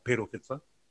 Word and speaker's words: Perofeta: So the other one we spoke about earlier, Ereatara Enari, Jonathan Ruru, Stephen Perofeta, so not Perofeta, Pero, is the pronunciation, Richie Mo'unga.